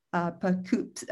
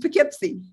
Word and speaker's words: Poughkeepsie: And I never say this right, but your city there, Poughkeepsie. – Thank you. Every time I still can't get it right. Poughkeepsie,